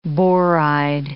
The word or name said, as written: boride